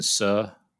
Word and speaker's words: Suh: I think Justin Suh. Did I say that right? – Yep. – Finally, for the first time in my life. Suh, yeah. Miscut in 58th in his 2 appearances here,